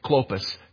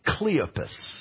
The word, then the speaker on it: Cleopas: Well, if you were to go into the King James Version, adds an E, the letter E, to this man's name, Clopas, to the name Cleopas. You'd have to pronounce it different because it's got an extra vowel in it.